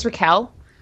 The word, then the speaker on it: Raquel: Um, uh, so my name is Raquel, but nobody when I was growing up could pronounce my name for reasons that continue to baffle me to this day. Um, they would call me Rachel or or Raquel or Rochelle or something.